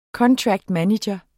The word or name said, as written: manager